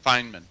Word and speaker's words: Feynman: Feynman? I pronounce that right? – Mm-hmm. – Right. – Feynman. – Feynman. – Feynman. – Yeah, F-E-Y-N-M-A-N.